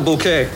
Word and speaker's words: Bucket: How do you spell Bouquet? – B-U-C-K-E-T. B-U-C-K-E-T. – Oh, Bucket.